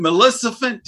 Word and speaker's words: Maleficent: The new, is it Maleficent? Did I pronounce that correctly? – Uh-huh.